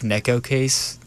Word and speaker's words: Neko Case: Like Neko Case? – Yes.